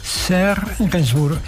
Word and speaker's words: Serge Gainsbourg: ¿Lo pronuncio bien? Serge Gainsbourg, perfecto.